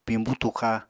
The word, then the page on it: piñbú tuxa: Apatani